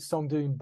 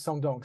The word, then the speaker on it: Sơn Đoòng: Well, it seems that a lot of Americans seem to pronounce it Son Doon, but the locals pronounce it Son Dong. So that's what I tend to go with.